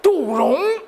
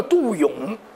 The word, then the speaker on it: 杜勇: 我跟你讲，听听就不就外号，我叫双钩蝎子杜荣，杜荣其实叫杜勇，因为静海县那个地方人管这个勇都念荣，就像天津人把俊都念成俊，这是习惯读法。